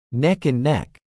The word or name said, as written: neck and neck